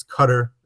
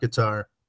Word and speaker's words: Qatar: You know, it's Qatar. It's not Qatar.